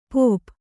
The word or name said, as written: ಪೋಪ್